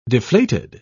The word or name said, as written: deflated